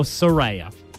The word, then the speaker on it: Suraya: Is it Soraya or Soraya? – We pronounce it Soraya. – Soraya. – S-U-R-A-Y-A.